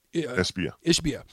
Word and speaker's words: Ishbia: Ishbia.